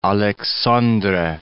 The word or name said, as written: Aleixandre